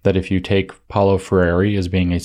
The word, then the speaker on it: Freire: Paulo Freire is his name, or Freire, to get it pronounced, I think, closer to correct.